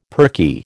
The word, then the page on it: perky: American English